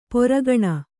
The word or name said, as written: ಪೊರಗಣ